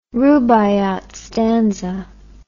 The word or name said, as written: rubaiyat stanza